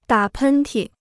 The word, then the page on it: 打喷嚏: dǎ pēn tì